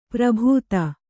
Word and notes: ಪರಿಭೂತ: pari bhūta

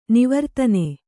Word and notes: ನಿವರ್ತನ: nivartana

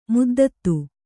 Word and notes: ಮದ್ದತ್ತು: kannada

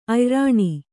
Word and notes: ಅಯ್ರಣೆ: ayraṇe

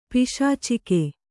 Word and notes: ಪೈಶಾಚಿಕ: paiśacika; Kannada

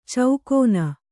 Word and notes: ಚೌಕೋನ: caukōna

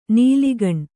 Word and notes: ನೆಲೆಗಾಣು: nelegāṇu

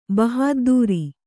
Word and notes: ಬಹದ್ದುರಿ: kannada

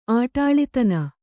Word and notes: ಆಟಾಳಿತನ: Kannada

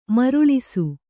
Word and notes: ಮರಳಿಸು: maraḷisu

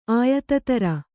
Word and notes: ಆಯುಧಧಾರಿ: āyudhadhāri

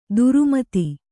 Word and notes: ಧರ್ಮೇತ: Kannada

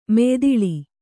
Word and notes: ಮೆಯ್ದಿಳಿ: kannada

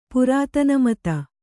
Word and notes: ಪುರಾತನಮತ: kannada

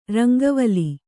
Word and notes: ರಂಗವಾಲೆ: rangavāle

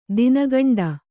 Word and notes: ದಿನಗಂಡ: dina gaṇḍa

